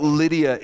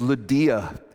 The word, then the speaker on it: Lydia: It's not Lydia, it's Lydia is her name.